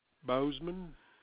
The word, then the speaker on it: Bozeman: R-A-N-D-Y. – Yes, ma'am. – And your last name? – Bozeman. – Spell that for me. – B-O-Z-E-M-A-N. – B-O-Z-E-M-A-N.